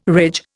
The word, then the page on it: ridge: Indian